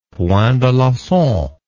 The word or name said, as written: point d'alencon